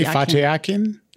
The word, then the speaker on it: Fatih Akin: I hope I'm pronouncing this correctly. Fatih Akin. – Yeah. – Yep.